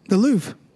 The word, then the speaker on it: Louvre: I don't know how to pronounce it correctly. The Louvre? – The Louvre.